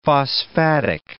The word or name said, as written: phosphatic